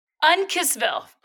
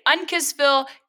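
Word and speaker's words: Uncasville: I'm in Unkissville. I've learned how to say it. Unkissville, Connecticut this weekend at Comics Roadhouse outside of the Mohegan Sun Casino.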